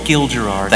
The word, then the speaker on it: Gil Gerard: Gil Gerard.